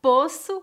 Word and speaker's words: poço: Poço com O fechado. Posso, do verbo poder, com O aberto.